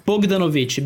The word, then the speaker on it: Bogdanovich: Como se fala? Bogdanovich? É isso? – Bogdanovich. B-O-G-D-A... N-O-V-I-C-H. Peter Bogdanovich.